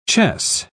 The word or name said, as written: chess